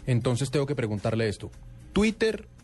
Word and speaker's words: Twitter: Eh, Twitter, porque la expresión en español es que la eh, T se pronuncia de esa manera, Twitter. Eh, decir Twitter sería estar utilizando una fonética inglesa para una palabra se puede pronunciar en español.